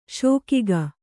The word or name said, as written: ಶೋಕಿಗ